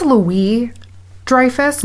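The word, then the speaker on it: Louis-Dreyfus: So, Julia Louis, I think it's Louis Dreyfus, no yes. S, silent S. Just, the S is just there.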